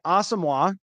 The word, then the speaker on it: Asamoah: Yeah, I think him and Brian Asamoah, that's the pronunciation now. I was chatting with somebody today out there and I was like, did that happen and I missed it? Like, no, that actually just, he just informed the team that that's how he wants it pronounced.